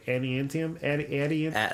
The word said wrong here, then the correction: Adamantium: Adamantium. – Adamantium.